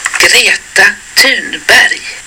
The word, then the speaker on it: Thunberg: I'll say Greta and Thunberg when I say it, but this is how it's pronounced. – Greta Thunberg.